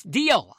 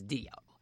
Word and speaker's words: Dio: Dio or Dio or whatever, however you want to read that, or however you want to pronounce the title or the name of the band. It's Dio. I pronounce it as Dio.